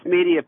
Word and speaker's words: Media: Medea, Pennsylvania. Am I saying that right, or is it Media, Pennsylvania? You're on the air with Senator Sanders. – Yeah, that's Media, Pennsylvania.